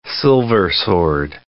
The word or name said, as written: silversword